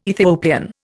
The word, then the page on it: Ethiopian: Indian